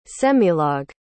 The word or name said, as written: semilog